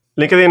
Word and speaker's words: LinkedIn: Bueno, pues LinkedIn es la herramienta que, bueno, realmente voy a decir, Santiago, tú lo estás pronunciando correctamente, LinkedIn. Yo creo que en todos los países de hispanohablantes le decimos LinkedIn, pues por eso estoy diciendo LinkedIn.